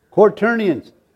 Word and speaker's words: quaternions: How do you say that word? Cornish, what? Cornelians. Who's got the right pronunciation? Huh? Quaternions.